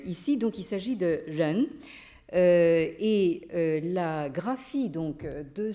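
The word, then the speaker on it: ren (仁): qui est donc le mot que l'on prononce en chinois contemporain, ren, transcrit R-E-N.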